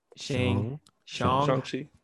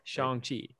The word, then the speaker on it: Shang-Chi: Shang-Chi. Jesus. Shang-Chi.